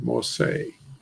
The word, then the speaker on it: Moshe: That's phonetically the word Moshe.